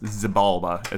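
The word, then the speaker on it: Xibalba: I keep calling him Zabalba, like the fucking Phantom Menace guy. It's Zabalba. Balba. That's Zabalba is how you actually pronounce it.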